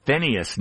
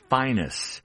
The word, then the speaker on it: Phineas: The three being Roy, what some people call Phineas Newborn Jr. He preferred the pronunciation Finus Newborn Jr. and Paul Chambers on bass, those three.